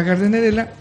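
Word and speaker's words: Gardnerella: Ahora, en, cuanto a la gardenerela, se está bien pronunciarla de las dos maneras. Gardenerela, porque lleva una D y luego seguida de una N, o GARDENERELA.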